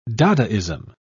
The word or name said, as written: dadaism